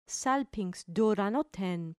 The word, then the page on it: salpinx duranoton: grec